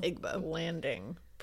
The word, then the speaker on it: Igbo Landing: Oh, that's British, so it sounds better. – She said Igbo. – Igbo Landing. – Igbo Landing. – You can just say it like that.